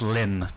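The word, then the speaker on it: Lympne: Now, one of them that's interesting, and I'm not even going to attempt to pronounce it because It's pronounced Lim Castro. – Lim. Yep.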